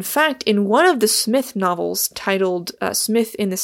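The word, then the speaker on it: Psmith: His four novels featuring Smith, with a silent P, were scattered over several years.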